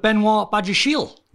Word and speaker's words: Benoit Badiashile: So Chelsea linked with Benoit Badiashile.